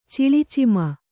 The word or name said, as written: ಚಿಲಿಚಿಮ